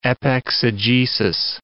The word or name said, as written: epexegesis